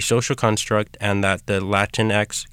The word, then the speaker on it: Latinx: La Liga is an online platform and print zine that showcases the many ways Latinx, did I say that right? – Yes.